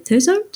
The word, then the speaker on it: Thurso: I hope that's how you say it, Thurso. Does that sound right? – That sounds right, yeah.